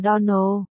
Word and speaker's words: Donald: Này, cô Shirley, cách phát âm đúng của tên của chúng tôi là Donald. Nhấn giọng vào âm tiết cuối cùng.